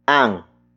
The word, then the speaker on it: ang: The next word is ang. Ang. Ang. Ang is spelled as A-N-G.